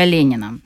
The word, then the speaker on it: Ленин: Назвали в честь русского революционера Владимира Ильича Ленина. Правда, на свой латиноамериканский манер в Эквадоре его зовут Ленин с ударением на последний слог.